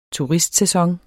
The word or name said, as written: turistsæson